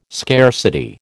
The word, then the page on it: scarcity: American English